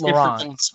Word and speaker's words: Laurent: It's Laurent.